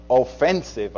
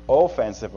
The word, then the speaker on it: offensive: Now, it's very important that we emphasize on the right syllable in that. It's not offensive apologetics. It's offensive apologetics, as in a basketball team that has a good offense or a football team that has a good offense.